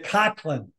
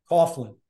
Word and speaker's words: Coughlin: I asked her if it was okay if I had the name pronounced the way it's spelled, Coughlin.